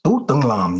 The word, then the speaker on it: Tu Tung Lam: And my Vietnamese name is very hard to pronounce, you know, it's pronounced Tu Tung Lam, you know, and people can't pronounce it.